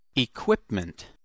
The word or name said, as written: equipment